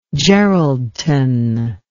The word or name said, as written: geraldton